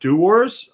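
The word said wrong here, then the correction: droëwors: Druivors, yeah. So Druivors is basically dried sausage.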